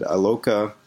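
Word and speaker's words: Iloka: Cincinnati safety, I believe you pronounce it Aloka.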